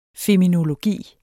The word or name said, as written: FEMINOLOGI